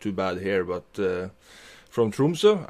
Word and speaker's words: Tromsø: Tromsø. Am I pronouncing that properly? – Oh, it's Tromsø.